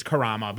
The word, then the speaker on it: Karama: Well, it is. It's an alternate spelling / pronunciation, but it is. It's Karama, but it is okay. – Karma. – Okay. – Because apparently karma, the word, comes from the Arabic word Karama.